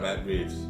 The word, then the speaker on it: Matt Reeves: Matt Reeves, right? I said it right. – Matt Reeves.